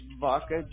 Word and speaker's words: Vaca: Vaca, just like Vaca Concrete.